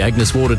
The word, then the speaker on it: Agnes Waters: Heading down to Agnes Waters. – Mate, it's pronounced Agnes Water.